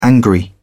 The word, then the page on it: angry: British English